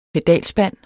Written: pedalspand

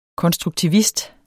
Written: konstruktivist

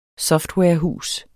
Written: softwarehus

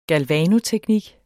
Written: galvanoteknik

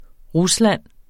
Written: Rusland

